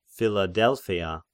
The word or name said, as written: Philadelpheia